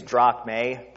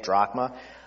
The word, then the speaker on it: drachma: Technically, it's drachme, but the right way to say it now, because it's still a coin that Greece uses today, is drachma.